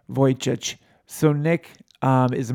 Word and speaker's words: Vujicic: Nick Vujicic.